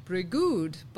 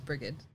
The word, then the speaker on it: Brigid: I googled it. And I even hit the play so because it had the pronunciation guide, which made it look like it was like Brigood. But like when I hit the play button, it was Brigid.